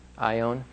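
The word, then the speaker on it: aion: One is the word cosmos, and the other is the word ion, with a long O. And this is the word that they're using there, ion, which...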